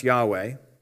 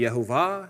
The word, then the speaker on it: Yahweh: First of all, YHWH is pronounced Yahweh. That is the name of God. Hello. That's a big deal. God has a name. His name is Yahweh. Scholars are not entirely sure that Yahweh is the correct pronunciation. It could be Yehovah, it could be with a W sound.